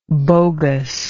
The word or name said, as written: bogusness